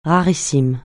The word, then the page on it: rarissime: francais